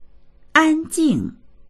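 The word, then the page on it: 安静: ānjìng; an1jing4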